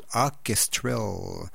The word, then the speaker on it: Hawkestrel: C'est Hawk, je vais essayer de le prononcer comme il faut, Hawkestrel. Hawkestrel. C'est tout nouveau, ça s'appelle The Future is Us.